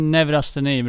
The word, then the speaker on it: neurasteni: Bland annat så skriver du om, nu ska vi se om jag uttalar det här rätt ens, neurosteni, amerikanska sjukan. Ja. – Eller vad säger man? – Neurasteni brukar man säga.